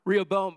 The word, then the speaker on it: Rehoboam: Where's my Bible scholar? How do you pronounce that? Rehoboam, but I said bum.